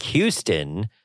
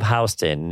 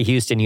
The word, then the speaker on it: Houston: That means south of Houston, which looks like Houston. – Yeah. – But it is pronounced Houston. – Exactly. – So it's south of Houston. If you're visiting New York City, you never say Houston. You say Houston, just to let you know.